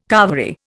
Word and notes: carvery: Indian

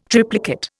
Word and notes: triplicate: Indian

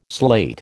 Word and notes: slate: American English

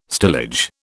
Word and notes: stillage: British English

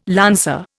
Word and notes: lancer: Indian

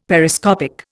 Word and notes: Periscopic: Indian

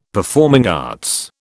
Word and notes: performing arts: British English

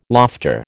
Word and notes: lofter: American English

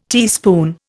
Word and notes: teaspoon: Indian

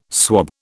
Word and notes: swab: British English

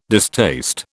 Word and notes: distaste: British English

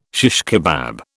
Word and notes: shish kebab: British English

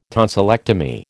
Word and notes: tonsillectomy: American English

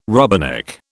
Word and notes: rubberneck: British English

